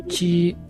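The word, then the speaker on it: xi: Ama pia X na I. Kwa Kichina tunasema ni chi. – Chi, X-I ni chi.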